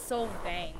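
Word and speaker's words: Solvang: It's called, Silvong? I'm not sure, but, yeah. – I'm not sure either. – How, pronunciation. Here, we'll get Google to pronounce it. Oh, it's Silvang.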